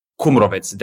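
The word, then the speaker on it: Kumrovec: Am I butchering that? – Krumovec, that's how we say it, yeah.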